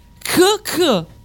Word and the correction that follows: ciak ciak: No, si pronuncia ciak ciak.